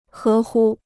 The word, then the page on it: 合乎: hé hū